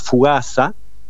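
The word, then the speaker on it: fugassa: Básicamente deriva de la palabra focaccia, del vocablo del dialecto genovés, que significa, que se pronuncia fugaza, ¿sí?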